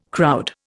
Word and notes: crowd: Indian

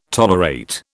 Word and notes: tolerate: British English

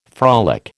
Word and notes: frolic: American English

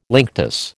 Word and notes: linctus: American English

